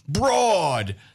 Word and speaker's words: Brodhead: The first A in the word Broadhead. It's spelled B-R-O-D-H-E-A-D. But it's said with an A. Broad, not broad.